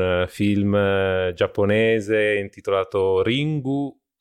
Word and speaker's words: Ringu: Nel senso che in verità è Ring, ma loro lo pronunciano Ringu perché esatto.